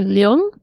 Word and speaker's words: Leung: Oh, I believe it's Tony Leung. – Okay. – And Maggie Chung. – Yeah. All right, I'm close Leung, enough.